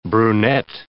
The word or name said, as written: brunette